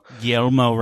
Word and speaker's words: Guilherme: I can't pronounce this word. I think it's Guilherme. Rambo? – Guillermo Rambo.